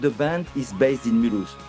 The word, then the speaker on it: Mulhouse: Mulhouse? How do you say that? – Mulhouse.